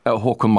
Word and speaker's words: Haakon: Haakon Magnus. – Exactly. Håkon. – Hugmans? – Exactly. – Just imagine you're the Swedish chef, but you sound cool. That's basically the Norwegian accents.